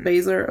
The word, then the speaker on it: Baszler: Is it Baszler or Baszler? – Baszler. – Okay. – That's usually how I hear it pronounced.